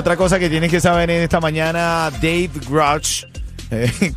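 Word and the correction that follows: David Grusch: David Grush,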